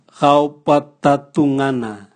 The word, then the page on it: gaobwatatungana: Raga